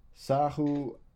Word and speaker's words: Sahuagin: You handled yourself well fighting the Saguin. I'm going to mispronounce this. Sahuagin. It is.